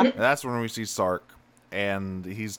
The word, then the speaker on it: Sark: Sark rhymes with narc.